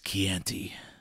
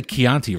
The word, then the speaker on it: Chianti: I ate his liver with some fava beans and a nice Chianti. He didn't say Chianti, right? He says Chianti, right? – He says Chianti. It's pronounced Chianti.